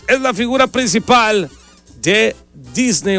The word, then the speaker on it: Disney: No se dice Disney, se dice Disney.